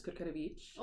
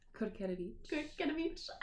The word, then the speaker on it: Kurkierewicz: Okay, so my name is Kay Kurkierewicz, but I'm Polish, so my last name pronunciation is Kurkierewicz. – Oh, so do it again, please. – Kurkierewicz.